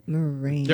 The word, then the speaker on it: Morena: Morena? I never know how to say her last name. – Morena.